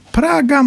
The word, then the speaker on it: Praga: Czyta się Praha czy Praga jednak? – Praga można czytać, ale chodzi o to, żeby od razu było wiadomo, o którą Pragę chodzi.